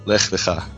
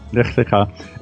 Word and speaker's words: Lech Lecha: Next week is Lech Lecha. Did I pronounce that right? – Lech Lecha. – Lech Lecha.